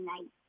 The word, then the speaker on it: knife: Yeah, I said knife. – So Charlie gave the word knife. – Oh, that's a good one, starting with a silent K, excellent.